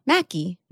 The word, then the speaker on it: Maki: I would also think Maki would be M-A-C-K, right? – Yeah, that's more like rhyming with tacky. The Midwest isn't tacky, but saying Maki does sound a little tacky.